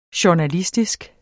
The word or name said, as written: journalistisk